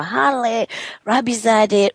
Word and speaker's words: Haleh Rabizadeh: I said, you know what, let me just start by going there and saying, you know, Haleh Rabizadeh.